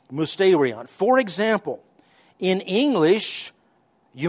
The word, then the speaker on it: mysterion: Actually, you would pronounce it mysterion in the New Testament.